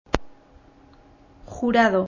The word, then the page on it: Jurado: Spanish